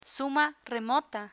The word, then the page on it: suma remota: Spanish